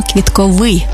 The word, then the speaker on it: квітковий: До речі, правильний наголос саме квітковий, бо стосується квітів. А квітковий – це про органи розмноження за допомогою квіток.